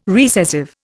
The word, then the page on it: recessive: Indian